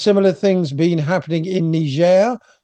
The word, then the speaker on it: Niger: I'll be using the French pronunciation, as Niger is part of the Francophone West African community.